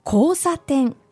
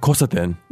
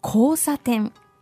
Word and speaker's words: 交差点: 交差点. 交差点. – So this first 交 is a long O. – Yeah. – Not 交差点. – No. 交差点。